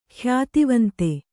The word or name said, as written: ಖ್ಯಾತಿವಂತ